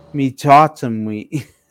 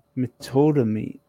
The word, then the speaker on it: meatotomy: Metotomy. Hold on. Metotomy. – What? – Metotomy. – Spell that, please. – Okay. M-E... A-T-O-T-O-M-Y.